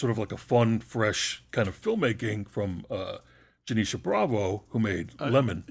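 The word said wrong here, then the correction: Janicza: Janixa, as it turns out. I thought it was Janisha, too. – Janixa.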